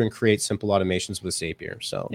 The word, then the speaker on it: Zapier: I believe it is Zapier just because they say it rhymes with happier.